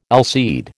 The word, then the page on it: allseed: American English